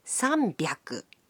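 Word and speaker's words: さんびゃく: But the pronunciation can be a bit tricky. 300 is pronounced sanbyaku. 600 becomes ropyaku. And 800 is pronounced hapyaku. On to thousands.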